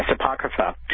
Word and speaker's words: Apocrypha: You have then the third, I hope I pronounce this right, Apocrypha? – Yes, Apocrypha.